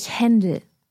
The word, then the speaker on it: Händel: Let's just run through the correct German pronunciation of those composers' names again. Johann Sebastian Bach, Georg Friedrich Händel, Richard Wagner, Robert Schumann, Joseph Haydn and Wolfgang Amadeus Mozart.